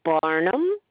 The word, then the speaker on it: Barnum: And the last name is Barnum.